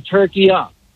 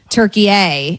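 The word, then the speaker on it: Türkiye: So it's a short A at the end, very simple. – Hold on. – Okay, so it's not what we would call, you and I are making it a hard A, turkey A, and it's turkey-a, saying.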